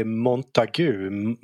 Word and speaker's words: Montagu: Vad uttalar han det? Han uttalar det Montagu.